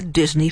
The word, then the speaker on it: Disigny: The book's title, as we've said, is The Other Disney, D-I-S-N-E-Y. And all along, we meet the Disney family, D-I-S-I-G-N-Y, pronounced Disney 2.